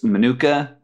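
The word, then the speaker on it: Mokena: And I want my pronunciation on this right. It's Manuka, Illinois? – Yes.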